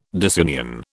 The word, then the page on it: disunion: British English